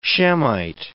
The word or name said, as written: Shemite